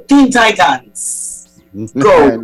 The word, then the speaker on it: Teen Titans Go: Teen Titans. – Go.